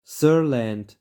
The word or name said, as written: Sirland